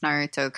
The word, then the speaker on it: Naruto: I appreciate that you pronounced Naruto correctly.